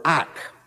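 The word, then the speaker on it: ak (אך): It's the Hebrew word ak, pronounced exactly that way, and it is very hard to translate it.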